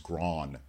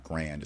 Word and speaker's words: Grand: How somebody said, you know, it's pronounced Gron, not grand. It's Gron.